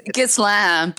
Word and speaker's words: Ghislaine: It's Ghislaine, but it sounds better to say Ghislaine.